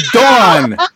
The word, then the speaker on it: Dawn: Dawn! Her name is Dawn. – Oh, damn. – I wonder why they spell it D-O-N, though. – D-A-W-N.